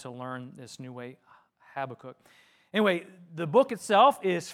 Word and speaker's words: Habakkuk: Now, I just want to say right off the bat that uh, my whole life I've always pronounced it uh, Habakkuk. – I don't know how you pronounce it, I've always pronounced it Habakkuk. And then I was watching the video I was just telling you about from Dr. Randy Smith, and he pronounces it Habakkuk.